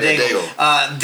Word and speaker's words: Daigle: That's how I say her name, unless I'm wrong in saying Daigle. – There you go, Daigle.